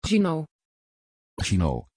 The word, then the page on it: Gino: Dutch